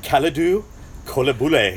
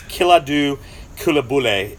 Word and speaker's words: Koulibaly: But anyway, so for people that aren't real familiar, and I'm not going to pronounce his name correctly, it's Kalidou Koulibaly is how I think it is.